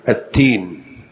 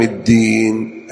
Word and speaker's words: الدين: إيش التين؟ إيه نعم، لا تقول مالك يوم الدين، التين، الدين الدال مشددة، فإذا نطقتها بالتاء بطلت الصلاة.